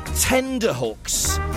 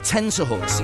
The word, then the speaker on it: tenterhooks: Hi to John in Ewent, who says he always used to say tender hooks instead of tenterhooks. He says he only found out a couple of years ago that he'd been saying it wrong.